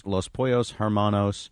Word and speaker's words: Los Pollos Hermanos: Los Pollos Hermanos name tag that says Gus.